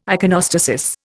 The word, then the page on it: iconostasis: Indian